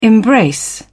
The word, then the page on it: embrace: British English